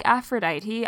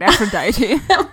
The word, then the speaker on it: Aphrodite: I can't have sex with you because, like Aphrodite, I was... Why did I pronounce that so weird? Aphrodite.